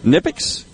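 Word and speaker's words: Knipex: Nipex, that's K-N-I-P-E-X. Had to verify the pronunciation of that. The K is silent.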